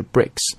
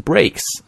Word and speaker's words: bricks: 那么在读这个 brick, 这个砖的时候一定要注意啊，这个 I 字母呢它发的是短音的 I, 所以读的是 bricks。很多中国同学会把它读成 breaks, 啊发 A 上面去了。尽量呢不要把它发成双元音的 A, 啊短音的 I 发成双元音的 A, 是中国同学经常犯的一个毛病。